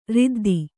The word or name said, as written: ರುದ್ದು